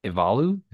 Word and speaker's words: Ivalu: Ivalu. – Short film live action. An Irish Goodbye. Evalu? Is that what it is? – Yes.